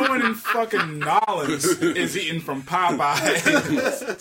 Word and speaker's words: New Orleans: And, you know, that leads us pretty directly into what's coming for WrestleMania 30 in New Orleans. – You mispronounced that. – Excuse me, in Nolens.